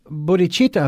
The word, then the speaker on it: bodhicitta: And that's what I call the bodhicitta effect. – You know, what's interesting is what we talked about in the first segment, which was, you know, a little depressing and disheartening, but this bodhicitta effect, did I pronounce that correctly? – Yeah.